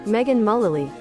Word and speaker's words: Megan Mullally: Megan Mullally.